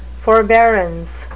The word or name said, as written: forbearance